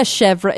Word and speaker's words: chèvre: But I like a chèvre.